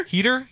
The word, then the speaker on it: Heder: Heder, yes. What's he from? – Isn't he from Saturday Night Live? – No. H-E-D-E-R. J-O-N-H-E-D-E-R.